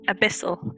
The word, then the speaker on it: Abyssal: Does anybody speak Abysmal? – Abysmal? – I do. – I don't know. – Yes, I do. – I speak Infernal. – Abyssal. Is that right? – Yeah. Sorry, mispronouncing shit.